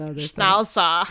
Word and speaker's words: Schnauzer: When I went to Wikipedia and I clicked the play button for pronunciation, it was like Schnauzer.